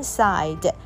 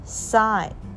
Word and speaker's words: sigh: 跟线的那个针那线我们有学过叫做 thread T-H-R-E-A-D Three drops of ruby red blood fell onto the snow 那他受伤了所以呢就有三滴像红宝石一般的血这个 ruby 是指红宝石 R-U-B-Y ruby red blood那三滴叫做 three drops of 三滴像红宝石一般的血呢 fell onto the snow 就掉到了这个雪上面 the queen sighed 那皇后呢就叹息 sigh S-I-G-H 不发音 sigh